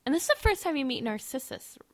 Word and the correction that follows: Narcissa: How do you say her name? – Narcissa. – Narcissa, whatever.